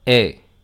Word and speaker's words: es: Habrán observado que hay letras que no se pronuncian, por ejemplo, tu e il se pronuncian igual, tu e, il e, esas s y la t no suenan.